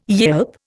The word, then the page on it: yelp: Indian